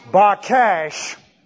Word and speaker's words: baqash: B-A-Q-A-S-H, pronounced bakash. P-A-L stem, the intensive stem in the Hebrew.